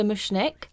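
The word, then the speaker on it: Mushnik: So this is a film about a timid flower shop assistant called Seymour who works for Mr. Mushnick. If I'm saying that right, Denny? – Yep.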